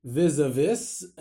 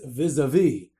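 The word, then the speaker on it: vis-à-vis: I mentioned the word recently, vis-a-vis, and really it's vis-a-vis.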